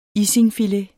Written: isingfilet

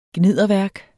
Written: gnidderværk

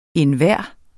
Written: enhver

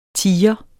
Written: tiger

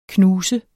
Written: knuser

